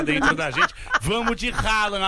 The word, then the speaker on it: Haaland: Where can you say Haaland? – Haaland? – Yeah, can you say his name? – Haaland? – Yes.